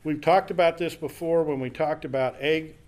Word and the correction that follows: Agag: um, um, Agag, Agag, Agag, there I can say it. If I start with a long A, I'll work it out.